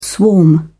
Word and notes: swarm: British English

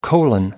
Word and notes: colon: American English

